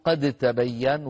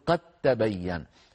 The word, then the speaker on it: قد تبين: لاحظوا كلمة وجب، وجب الإدغام، نحو قد تبين، فلا نقول قد تبين وإنما نقول قد تبين، نحول الدال إلى تاء وندغم التاء في التاء فيصير النطق بتاء مشددة.